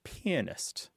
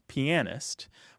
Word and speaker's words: pianist: Well, traditionally, the pronunciation of this word is pianist, with an emphasis on the first syllable, pianist. There is a second pronunciation, which is less common, and some dictionaries don't even include it, which is pianist.